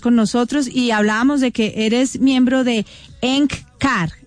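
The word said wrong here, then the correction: Eckankar: Se pronuncia ECANCAR. S-K-A-N-K-A-R. Significa colaborador. Significa colaborador con Dios.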